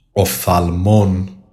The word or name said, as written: OPTHALMON